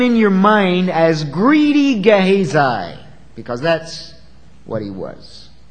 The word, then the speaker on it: Gehazi: His name is Gehazi. Now that's the way it's pronounced to the best of my ability. I've checked that out. There are probably other ways to pronounce it. That's not the way you would pronounce it in Hebrew. But I think in English that's probably close enough. All right? G-E-H-A-Z-I, Gehazi, not one of your more famous Old Testament characters.